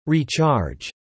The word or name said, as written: recharge